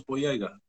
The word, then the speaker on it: Boyega: No, you said it pretty close, Boyega.